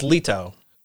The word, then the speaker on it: Leto: In the traditional Greek, yes. It's pronounced Leto.